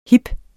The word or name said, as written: hip